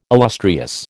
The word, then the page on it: illustrious: American English